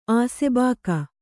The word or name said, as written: ಆಸೆಬಾಕ